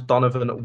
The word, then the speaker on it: Donovan: My name's Donovan Wint, D-O-N-O-V-A-N, because people spell it wrong, Wint.